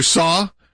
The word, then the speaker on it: Cao: Which, by the way, that's how we say Pastor Saw. But it is spelled CAO, so I make sure people understand that when they're going, why am I going to CAO?